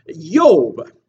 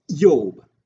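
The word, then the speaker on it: Yob (איוב): So the book of Job is named after its main character, who is pronounced Job in English, Yob in Hebrew. And in Hebrew, it has a silent letter, Aleph, right before the U. So it's Yob.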